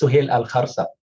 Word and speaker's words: Suhail Al Kharsah: Now, I know that I don't have the Arabic tongue to say that last name correctly. Suhail, would you say that correctly for me? – Thank you, Tim.